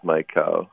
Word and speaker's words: Maikau: I've always been told by my friends to pronounce every vowel. So are you Maikau or are you Maikau? How do you say your name, Alika? – This one is just Maikau, yeah.